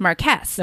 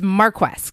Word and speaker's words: Marquess: Which looks like it's pronounced Marquess. – No, exactly. – But it's Marquess because they just like lean real against it.